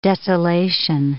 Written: desolation

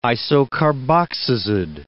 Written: isocarboxazid

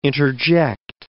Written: interject

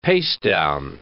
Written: pastedown

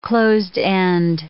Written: closed-end

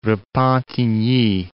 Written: Repentigny

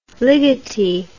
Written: ligeti

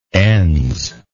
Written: ens

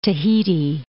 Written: Tahiti

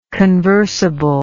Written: conversably

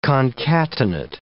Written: concatenate

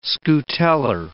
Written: scutellar

